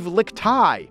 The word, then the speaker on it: Lickteig: Steve Lickteig, executive producer of Slate Podcasts, actually is Steve Lickteig. "There's a G at the end, but let's pretend there's not," he tells me.